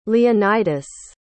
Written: leonidas

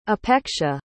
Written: apeksha

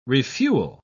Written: refuel

